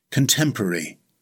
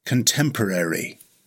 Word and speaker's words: contemporary: In British English, it has four syllables. Stress the second syllable. It's pronounced contemporary. In American English, it has five syllables. Again, stress the second syllable. It's pronounced contemporary.